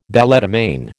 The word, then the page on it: balletomane: American English